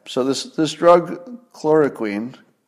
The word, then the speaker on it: chloroquine: Chloro chloroquine. Chloroquine.